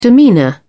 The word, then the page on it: demeanour: British English